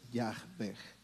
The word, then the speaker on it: Yahveh: La más aproximada es Yahveh, las H sonando como J.